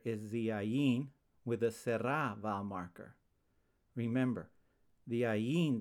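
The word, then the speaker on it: tsere: This vowel marker is called serra and is pronounced e as in pet.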